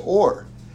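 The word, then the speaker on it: or (עור): Because ayin and aleph are both silent letters, so it's both pronounced or.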